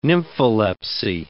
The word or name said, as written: nympholepsy